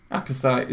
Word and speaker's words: apposite: Apposite. Is that how you say it? – Apposite. – Apposite, yeah. It's like opposite, but spelt with an A in front.